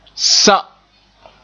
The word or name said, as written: sa